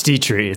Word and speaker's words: Detrixhe: It's Dietrich. It's a lot easier than it looks.